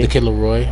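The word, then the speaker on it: The Kid LAROI: Is that how you say his name? – The Kid LaRoy.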